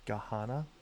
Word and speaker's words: Gahanna: Gahanna? – I guess. G-A-H-A-N-N-A. – Gahanna it is.